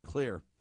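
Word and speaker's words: Xlear: called Clear, spelled with an X, Clear.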